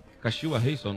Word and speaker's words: Kashiwa Reysol: Kashiwa Reysol, não foi? – Pronúncia certinha. Pronúncia boa.